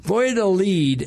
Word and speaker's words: Valladolid: Valladolid is the second oldest city on the Yucatan Peninsula of Mexico. It's spelled V A L L A Dolid, D O L I D, Valladolid.